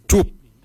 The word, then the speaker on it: djob: Quart is spelled G-O-O-R-D. Quart. Quart is your heart. For kidney, we say joop. Joop is spelled D-J-O-B.